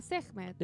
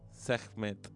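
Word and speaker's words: Sekhmet: Se llama Sehmet. – Decís Sehmet. – Met. – Ahora todo junto. – Sehmet. – Muy bien. – ¿Lo dije bien? – Sí.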